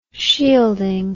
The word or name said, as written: shielding